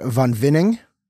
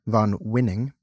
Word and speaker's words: Von Winning: This wine is from the good folks at Von Winning, spelled with a W, like winning, Von Winning.